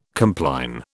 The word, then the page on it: compline: British English